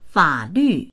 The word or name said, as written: fa3lv4